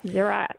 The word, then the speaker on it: Zerah: Judah, the son, the father of Perez, and Zerah. Is that how you say it? – Yep, Zerah.